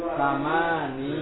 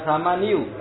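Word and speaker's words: samani (ثماني): Ini samani gitu ya. Bukan samaniu, tapi samani. Samani talibatin.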